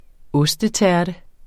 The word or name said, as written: ostetærte